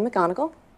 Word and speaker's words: McGonigal: Hi, I'm Kelly McGonigal. I'm a health psychologist at Stanford and the author of The Willpower Instinct. はい、ということで、ケリー・マクゴニガルさんのお名前の発音は I'm Kelly McGonigal. McGonigal? っていう感じでですね、まあこれだけでもなかなか難しいなというところでした。